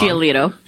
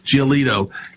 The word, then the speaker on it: Giolito: How do you say it? Giolito. You know, you're the baseball guy. – I think it's Tom. – Giolito. – Giolito.